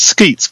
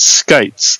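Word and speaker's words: Skeates: And I used to think it was Skeets, but it's Skates.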